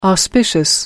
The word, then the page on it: auspicious: American English